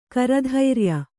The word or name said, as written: ಕರಧೈರ್ಯ